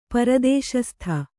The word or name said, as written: ಪರದೇಶಸ್ಥ